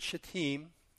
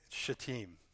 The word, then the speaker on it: Shittim: And this is a place, that word Shittim, and that's how it's actually pronounced for all of you that are reading the word. Shittim.